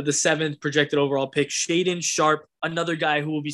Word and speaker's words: Shaedon Sharpe: Talk to me about Shaedon Sharpe.